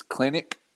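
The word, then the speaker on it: Kelenic: And I do think the rookie of the year will come out of the Mariners, and I always mispronounce his name. It's Klenick. Jared Klenick. Yeah, I think he's got a chance to win the American League Rookie of the Year.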